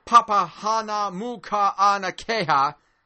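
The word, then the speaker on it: Papahānaumokuākea: He more than quadrupled the size of, and say this right, Papahanaumokuanakeha. – You did well.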